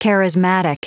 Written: charismatic

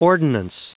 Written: ordinance

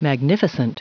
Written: magnificent

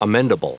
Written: amendable

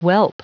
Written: whelp

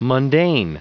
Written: mundane

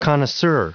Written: connoisseur

